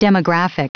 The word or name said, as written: demographic